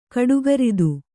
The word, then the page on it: ಕಡುಗರಿದು: kaḍugaridu